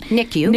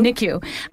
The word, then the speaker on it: NICU: NICU. – NICU, with your baby daughter Tess.